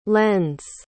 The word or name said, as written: lends